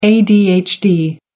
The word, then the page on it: adhd: American English